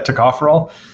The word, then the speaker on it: tocopherol: And then I found this interesting, tocopherol, I think it's pronounced. – Yeah, tocopherol.